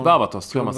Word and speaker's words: Varvatos: John Varvatos.